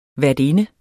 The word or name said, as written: værtinde